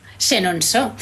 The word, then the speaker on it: Chenonceau: Chenonceau, muy bien, muy bien a la pronunciación, ¿eh? – Bueno, genial, sí.